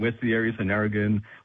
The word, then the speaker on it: Narrogin: Now, those G's, mate, they're the soft G's, so Wagon and Narrogin.